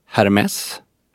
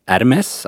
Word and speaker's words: Hermès: Sen, vad jag har förstått, så uttalas det Hermès. Alltså du lägger bort H-et. Men du ska definitivt uttala S-et. Och vissa säger armé. Och där tycker jag ju att armé är det helt felaktiga.